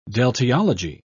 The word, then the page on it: deltiology: British English